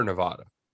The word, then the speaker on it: Nevada: Northern Nevada. Yeah. And wait, we have to also agree. Are we Nevada or Nevada? – It's Nevada. – Nevada? Okay. Okay. Yeah. We'll say it how the locals say it.